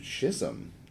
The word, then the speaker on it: Chisholm: Chisholm. That's how you say that. – Chisholm. Yeah, I know. It's spelled different.